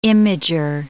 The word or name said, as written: imager